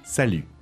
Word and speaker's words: salut: Just notice that you don't pronounce the T at the end. Salut.